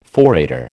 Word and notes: forrader: American English

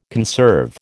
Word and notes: conserve: American English

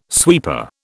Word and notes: sweeper: British English

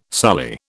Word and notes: sully: British English

